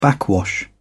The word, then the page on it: backwash: British English